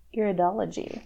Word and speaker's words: iridology: I never say it right. Iridology. – Nailed it.